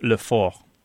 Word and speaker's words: fort: In French, though, it would be F-O-R-T, and the T would not be pronounced, so it wouldn't be pronounced fort either. – It would be pronounced le fort.